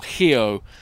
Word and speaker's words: Rio: And so I wonder what your take of Rio was from seeing both sides of it. – It was so interesting. And Rio, or Rio, as the locals called it, the R is an H there.